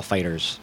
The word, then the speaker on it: FighterZ: Dragon Ball FighterZ. The Z is silent.